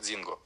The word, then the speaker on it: Дзингу: И пару текстов о императрице китайской, которая легендарная была, но она там немножечко по-другому произносится, как Дзингу.